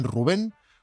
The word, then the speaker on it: Rubén: Pero él asegura ser Blades, aunque lo único que quiere es que le llamen Rubén con acento en la E.